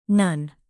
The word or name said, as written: none